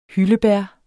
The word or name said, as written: hyldebær